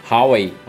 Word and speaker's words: Huawei: A, a, corrija a pronúncia aí. Huawei. Huawei. Huawei. Huawei. Huawei. Huawei. Isso.